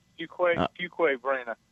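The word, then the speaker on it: Fuquay-Varina: Is it Fuque Verena, North Carolina? – Yes, Fuque Verena.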